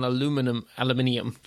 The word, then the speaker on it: aluminium: Because you said it right the first time. – Officially, actually, within the IEEE, you guys got sulfur spelt the sensible way and we got aluminium said the sensible way.